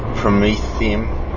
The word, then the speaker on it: promethium: Lanthanum, cerium, praseodymium, neodymium, promethium, that's the way to pronounce it, mate.